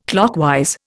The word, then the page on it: clockwise: Indian